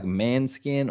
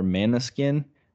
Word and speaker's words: Måneskin: It looks like Manskin or Måneskin. And I was like, is this band called Manskin? They're not. They're called Måneskin. Do you know that band? – Never heard of the band ever.